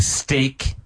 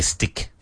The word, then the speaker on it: steak: And because people say stick, but it's steak.